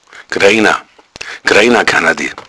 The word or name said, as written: greina